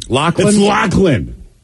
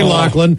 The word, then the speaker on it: Loughlin: L-O-C-K, Loughlin. – Yeah, I saw it a couple of different. I was saying it wrong, too. – I think I said Loughlin earlier. I thought the G was silent.